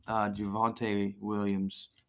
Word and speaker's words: Javonte Williams: I think him and Javonte Williams – say that right.